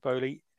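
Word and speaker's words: Boehly: So, Todd Bowley, I think he pronounced his name, Todd Bowley, LA Dodgers owner.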